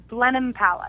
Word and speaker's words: Blenheim Palace: And correct me if I'm saying this wrong, Blenheim Palace, Oxfordshire? – Blenheim Palace.